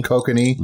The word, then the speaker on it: Kokanee: Kokanee.